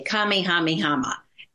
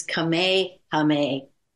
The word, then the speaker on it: Kamehameha: I would say Kamehameha. And everybody would laugh. And they say, no, that's not Hawaiian. It's Kamehameha. Or whatever it is.